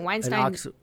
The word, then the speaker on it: Weinstein: It's Weinstein. – Nice job, Weinstein. – So in German, the E-I is pronounced like an I.